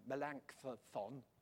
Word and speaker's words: Melanchthon: Melanchthon.